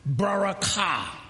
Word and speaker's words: barakah: Now, the blessing is a word, barakah. Barakah. The accent is on the ka, just like you, God changed Abram's name to Abraham.